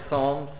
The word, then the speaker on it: psalms: We usually don't pronounce the P. Well, here we're not pronouncing the S. It's palms instead of psalms.